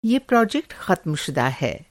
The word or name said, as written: ख़त्मशुदा